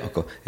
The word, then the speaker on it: Alcoa: Alcoa.